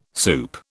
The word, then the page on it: soup: British English